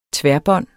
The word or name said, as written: tværbånd